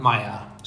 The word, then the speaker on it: Mayar: So um, we have with us then Mr. Habib um, Rahman Mayar.